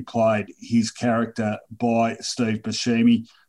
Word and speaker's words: Buscemi: Yeah, Buscemi.